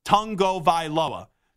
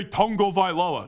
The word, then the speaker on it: Tagovailoa: We've had Tua on the show when he was at Alabama a bunch, and there's a video where someone asked him, how do you correctly pronounce his name? And he's like, Tagovailoa. So I always get a kick whenever somebody is like, why do you say Tagovailoa?